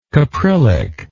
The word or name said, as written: caprylic